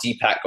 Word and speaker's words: Deepak: And the interesting part, Deepak, of course, sounds a lot like Tupac.